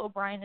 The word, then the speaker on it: O'Brien: Rachel is spelled R-A-C-H-A-E-L. O'Brien is O-B-R-I-E-N. And on Instagram and Twitter, I am Rachel N. O'Brien, R-A-C-H-A-E-L-N-O-B-R-I-E-N.